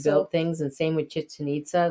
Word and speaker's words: Chichen Itza: Yeah, Chichen Itza. Think of chicken and nitzas.